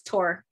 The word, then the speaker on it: Thor: It's not Thor, it's Tor, just PSA.